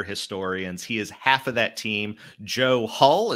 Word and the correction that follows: Huth: is that the correct way to pronounce his last name? – Huth. – I apologize. Joe Huth is the other member of that team.